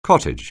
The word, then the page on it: cottage: anglais